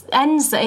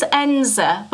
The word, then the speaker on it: Edensor: And it's Enza. It's pronounced Enza, but it's spelt E-D-E-N-S-O-R, but you say Enza.